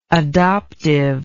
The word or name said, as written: adoptively